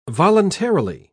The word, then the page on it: voluntarily: British English